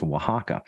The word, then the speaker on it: Oaxaca: It's spelled Oaxaca, but it's pronounced Oaxaca.